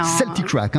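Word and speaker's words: Celtik'Raic: Celtic Crack, hein, ça c'est le nom du groupe.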